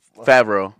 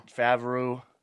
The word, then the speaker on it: Favreau: Those I don't know how to pronounce his name. John Favreau. – Favreau. – Yeah, Favreau. John Favreau.